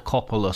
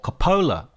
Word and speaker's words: Coppola: We're going to be talking about A Path to Paradise, a Francis Ford Coppola story, or Coppola, I should say, actually. I mispronounced his name all the way through this podcast, so apologies for that.